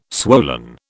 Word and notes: swollen: British English

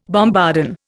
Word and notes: bombardon: Indian

